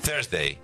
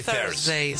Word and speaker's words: Thursday: Thursday. Decí Thursday. – Thursday. – ¿Viste? No me sale. Yo le digo con T, como se escribe.